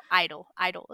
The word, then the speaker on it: (G)I-DLE: Next is my favorite girl group with Queencard, and it's G-Idle. Well, I-DLE is how you say it. The G.